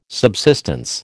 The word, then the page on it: subsistence: American English